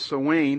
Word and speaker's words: Samhain: It's spelled S-A-M-H-A-I-N, but it's pronounced Samhain.